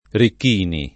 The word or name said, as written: Ricchini